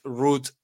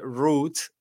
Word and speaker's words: route: Which is the best route to the museum? ¿Cuál es la mejor ruta para el museo? O recuerden que ese route o route admite las dos pronunciaciones. Route, route. Las dos son válidas.